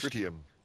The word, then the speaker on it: tritium: Tritium. – Tritium? I don't think the emphasis was on the first syllable.